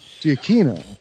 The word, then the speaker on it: Giacchino: I thought a CH in Italian was Giacchino. – Giacchino. – Well, but that is true. That is true. But that would be the Italian pronunciation.